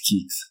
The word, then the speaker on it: Keex: So the very first sneaker brand I'll be sharing with you today is Kicks, and it's spelled K-E-E-X.